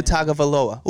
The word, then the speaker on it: Tagovailoa: And yeah, Tua Tagovailoa.